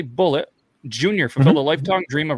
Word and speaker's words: Bulleit: I think it is because Bullet. Bar Rescue, they were pushing Bullet whiskey a lot on Bar Rescue, and they always pronounced it Bullet.